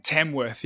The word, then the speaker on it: Tamworth: How far is Melbourne from Tamworth? Is that how you say it? – Tamworth, yeah. So Tamworth, it's about, let's see.